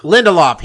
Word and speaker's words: Lindelof: I think it's Damian Lindelof is his name, the guy who made Lost. Is that how you say it? – Lindelof.